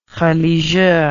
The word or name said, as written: religieux